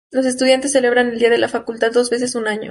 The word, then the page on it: facultad: spanisch